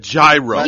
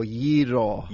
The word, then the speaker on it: gyro: And today we're going to make you an ordinary Greek, and you'll pronounce it the right way. We'll drop the G. We'll drop the G and just imagine it's not there and go gyro.